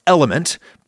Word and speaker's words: LMNT: It's actually pronounced Element, but this is a radio program, so it's spelled LMNT. Pronounced Element.